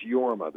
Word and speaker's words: Jorma: And this is not a criticism, so don't wince, but it's Jorma. The J is pronounced like a Y.